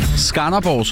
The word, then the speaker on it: Skanderborg: Skanderborg, som jeg har hørt, det skal udtales. Skanderborg.